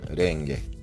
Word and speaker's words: Renge: En Japón, los caracteres chinos para flor del loto se pronuncian Renge, y el carácter para la enseñanza del Buda o un sutra se pronuncia Kyo.